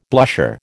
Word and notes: blusher: American English

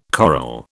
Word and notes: coral: British English

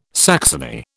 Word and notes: saxony: British English

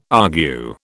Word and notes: argue: British English

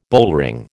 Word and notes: bullring: American English

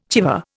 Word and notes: chèvre: Indian

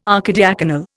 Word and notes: archidiaconal: Indian

